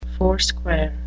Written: foursquare

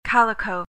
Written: calico

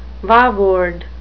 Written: vaward

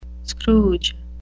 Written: scrooge

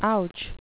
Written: ouch

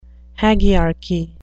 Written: hagiarchy

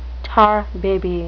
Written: tar baby

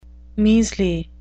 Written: measly

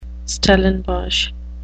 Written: Stellenbosch

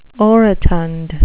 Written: orotund

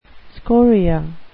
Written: scoria

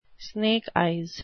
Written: snake eyes

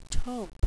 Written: taupe

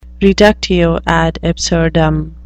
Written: reductio ad absurdum

